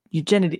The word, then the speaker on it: Eugenides: Abigail says, pronounce it like you're an ancient Greek. – Ancient Greek. eugenides. No, I've eugenides. Eugenides. There. That sounds. You're right. That sounds better.